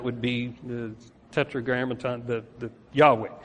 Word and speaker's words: Yahweh: Yahweh is the best way we know how to pronounce it.